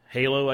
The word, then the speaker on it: HaLow: So you may have seen this already, but the Wi-Fi Alliance actually introduced a low-power, long-range Wi-Fi, which is called Wi-Fi Halo, I guess you could call it, but it's spelled H-A-L-O-W.